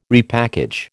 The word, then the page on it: repackage: American English